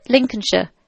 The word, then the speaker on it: Lincolnshire: Lincolnshire. – It says Lincolnshire. – No, but it's Shire is sure. – Why don't they just spell it as H-U-R?